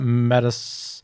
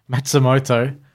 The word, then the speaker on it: Matsumoto: Well, Japanese wrestling royalty Meiko Satomura and Hiroyo Matas- Matsumoto.